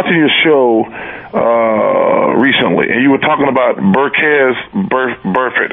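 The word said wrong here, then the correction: Vontaze Burfict: I think. Did I pronounce his name? – Vontaze Burfitt. – Vontaze, yeah.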